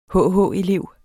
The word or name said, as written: hh-elev